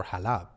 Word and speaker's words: Halab: So, for example, there's a city in Syria called Aleppo, mm-hmm. which is uh, Farsi for Halab. Yeah. And I said Aleppo.